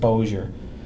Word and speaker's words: Bossier: I called it Bossier City, and I knew that was wrong. I knew they had to have some kind of bizarre, unique pronunciation. – Well, it's spelled Bossier. – Yeah, it's got two S's, but somehow they pronounce it Bossier.